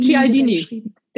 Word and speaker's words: Cialdini: von Robert, spricht man ihn Cialdini, Cialdini, Cialdini. Der Cialdini, Cialdini danke.